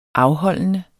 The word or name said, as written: afholdende